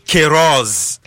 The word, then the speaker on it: Queiroz: Yeah, so Carlos Queiroz, that's the correct pronunciation. – Queiroz. – Queiroz. The Q-U-E-I. – All right. – It's K in English. So it's Queiroz.